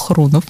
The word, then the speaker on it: Хрунов: Я уточнила, как ударение правильно ставится, он мне сказал Хрунов. Вот, с Машей Козиной и Няхиной из э, Михалкова.